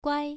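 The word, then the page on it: guai: tone 1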